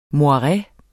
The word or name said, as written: moireets